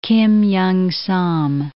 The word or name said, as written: Kim Young Sam